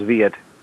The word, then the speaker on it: Veit: It's reasonably close. It's two syllables, Veit.